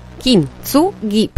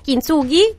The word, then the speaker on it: Kintsugi: Kintsugi, perché ci hanno insegnato che nelle parole giapponesi l'accento va in ogni sillaba, quindi capito, non si può sbagliare. Kintsugi, Kintsugi, vabbè, come volete.